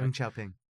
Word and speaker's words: Deng Xiaoping: So we have Joe Biden claims that he worked with Chinese leader Deng Xiaoping. I don't know if I said that right, who cares anyway. – Deng Xiaoping.